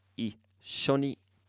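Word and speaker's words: ishoni (いっしょに): All right, our next vocabulary word means together, and it's pronounced ishoni. Ishoni. Ishoni.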